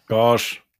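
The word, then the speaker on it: Gauge: Jetzt geht es um Iberian Gouge oder Gouge. Du musst mir das als Englischlehrer nochmal. – Gouge. – Gouge, okay, Gouge. Man könnte es auch sächsisch aussprechen mit Gouge.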